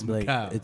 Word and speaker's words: Mikal: No, I'd be like, it's Mikal. Mikal. – Mikal. – Mikal. – Like the Russian people, shout out to all the Russian people. Thank you for pronouncing my name right.